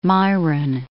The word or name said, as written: Myron